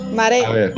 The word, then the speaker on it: Marais: La bañera de su piso en el barrio de Marais. Mogos. Mogos. – Marais. – Mogos. – ¿Cómo se dice esto? – Maré. mare, O sea, la A, la I y la S.